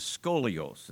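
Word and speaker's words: scolios (σκολιός): That word is scolios, that's how it's pronounced.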